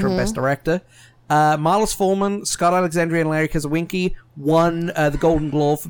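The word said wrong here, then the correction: Karaszewski: Somebody please let this man know how to pronounce that. – Oh, I know. It sounds like I'm ignorant, but I can't. – Zinski. – Zinski, okay. – Karazuski. – That's what I said.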